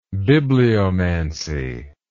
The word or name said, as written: bibliomancy